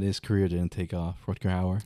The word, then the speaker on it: Rutger Hauer: Rutger Hauer, that's how you pronounce it.